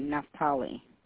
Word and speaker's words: Naftali: N as Nancy, A, F as Father, T as Tom, A-L-I. – Okay, Naftali.